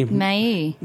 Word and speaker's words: Maille: M-A-I-L-L-E. – May.